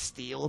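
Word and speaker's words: Stihl: H-I-L. – I-H-L. – I-H-L. – So it's still, I think it's pronounced steel, but it's not steel. – Right. – It's just steel.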